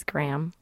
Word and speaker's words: Graham: My last name is Graham. – Graham. – It's a toughie, I know. – Graham, G-R-A. Like the cracker. – Is it G-R-A-H-M? – A-M.